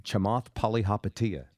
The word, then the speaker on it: Chamath Palihapitiya: And then there's Chamath Palihapitiya.